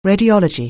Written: radiology

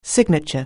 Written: signature

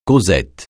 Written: causette

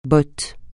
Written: botte

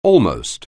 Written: almost

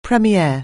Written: premiere

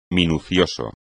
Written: minucioso

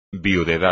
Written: viudedad

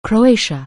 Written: Croatia

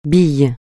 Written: bille

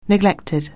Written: neglected

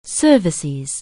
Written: cervix

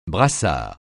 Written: brassard

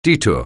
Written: detour

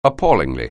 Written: appallingly

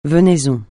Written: venaison

